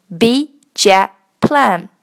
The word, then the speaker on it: big jet plane: 把 big 的 g 不要了，把 jet 的 t 不要了，直接念成 b jet plan。